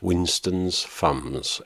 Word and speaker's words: Winston's Fumbs: Right, I'm going to have a standalone track now by Winston's Fumbs, and it's not me being lazy, that's how it's pronounced. Winston's Fumbs, F-U-M-B-S, take it from me.